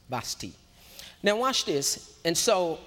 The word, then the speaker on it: Vashti: That was a queen before Esther, and it's pronounced, it is spelled V-A-S-H-T-I, but it's actually pronounced Vashti, because the T, it's not the T, the I is silent, and in the Hebrew is Vashti.